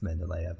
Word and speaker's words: Mendeleev: I, I only had this one because Carolyn said you need to you need to tell everyone that it's actually pronounced Mendeleev.